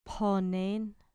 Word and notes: phonen: grec

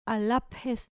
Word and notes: all aphes: grec

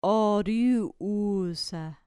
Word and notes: oruusa: grec

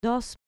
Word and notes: dos: grec

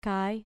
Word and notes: kai: grec

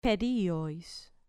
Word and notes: periois: grec

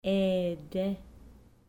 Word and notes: ede: grec

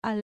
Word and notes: all: grec

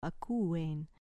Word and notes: akuein: grec

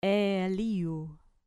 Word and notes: eeliu: grec

